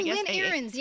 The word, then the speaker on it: Ahrens: Lynn Ahrens.